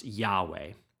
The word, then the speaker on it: Yahweh: And one interesting fact about this word is that some consider the pronunciation of Yahweh as the actual sound of breathing. Yah as the inhale and Weh as the exhale.